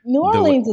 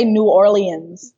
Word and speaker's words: New Orleans: New Orleans is fine. As long as you don't say New Orleans.